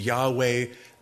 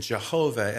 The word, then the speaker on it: Yahweh: Probably the accurate way to pronounce it is Yahweh, the most popular through the years has been Jehovah. And that's been, I think, by a misunderstanding or an inaccurate understanding on pronunciation in the Hebrew.